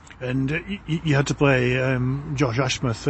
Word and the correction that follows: Ashman: at right back today, but I always get his name wrong. It's Ashman, isn't it? – Yeah, Josh Ashman, yeah.